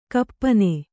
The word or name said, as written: ಗೋಪನ